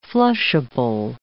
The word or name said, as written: flushable